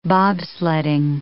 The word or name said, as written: bobsledding